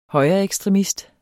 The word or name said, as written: højreekstremist